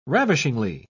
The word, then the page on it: ravishingly: British English